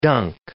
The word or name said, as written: dunk